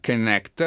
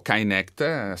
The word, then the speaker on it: Kinect: Allora, intanto stiamo parlando di un oggetto che si chiama Kinect o Kinect, secondo le pronunce,